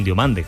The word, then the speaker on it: Diomande: Así que parece que José Mourinho le está probando en esa alternativa de banda diestra, donde se jugaría el puesto con Diomande, claro. – Diomande, por cierto, hoy lo has dicho en redacción, Diomande, ¿eh? Nos tenemos que acostumbrar. No Diomande, Diomande se pronuncia.